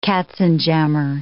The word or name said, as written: katzenjammer